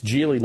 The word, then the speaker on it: Geely: So it's Geely, not Geely, even though it's spelled obviously G-E-E-L-Y, but they pronounce it Geely.